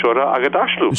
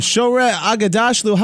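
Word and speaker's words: Shohreh Aghdashloo: Say hello to Shore Agadashlu. – Shore Agadashlu, how are you? – I'm fantastic, thank you so much.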